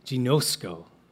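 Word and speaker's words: ginosko (γινώσκω): The Greek word there is ginosko. Everybody say ginosko. Yeah, it's like a soft G, right?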